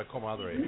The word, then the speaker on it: comadre: Anyway, his wife, good friends with my comadre, although there's she could be better, but Mexico, there's no age difference. – Tell everybody what a comadre is.